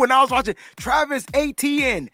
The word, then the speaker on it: Etienne: His last name is Etienne. – It's dope. How do you say it? – Etienne. – Yeah. No, that's not how they said it. That wasn't it.